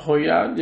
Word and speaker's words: Højgaard: Højgaard, yeah. – Yeah, Højgaard, is that how you say it? – Yeah. – I didn't know what that cross in the O. – That means like O-I-I. – Højgaard.